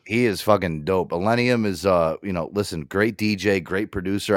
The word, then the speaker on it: Illenium: Illium. I know I'm saying his name wrong. – Illenium.